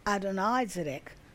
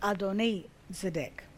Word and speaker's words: Adonizedek: It was after that that the king of Jerusalem, and his name was Adonizedek, not Adonizedek, but Adonizedek, stirred up fear in them. In Yehoshua chapter 10 and verse 1, it says, when Adonizedek,